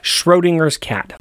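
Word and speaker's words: Schrödinger: Oh wait, can you help me pronounce this? Shrodun Schrodinger's Schrodinger's cat.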